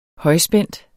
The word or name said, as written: højspændt